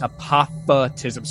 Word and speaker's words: apophatism: Maybe I said it weird. It's apophatism. So it's spelled apophatism. – A-P-O-P-H-A-T-I-S-M.